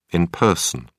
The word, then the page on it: in person: British English